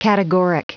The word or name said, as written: categoric